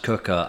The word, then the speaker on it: Quooker: Welcome to the Dear Lovejoy podcast, sponsored by Cooker, spelled Quooker, pronounced Cooker.